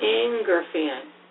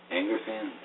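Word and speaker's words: aangirfan: The blog's name is A-A-N-G-I-R-F-A-N. I can't even pronounce that as a word. – Angerfan. – Angerfan.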